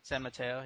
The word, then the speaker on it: San Mateo: San Mateo, yeah.